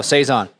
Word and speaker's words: Saison: Oh, is that how it's pronounced? Oh, so you're the BGCP guy, so you know. – So, no, Saison.